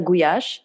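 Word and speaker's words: gulyás: Да, вот без буквы «л» будет правильно «гуяш». – Гуяш. Именно так его называют венгры, да.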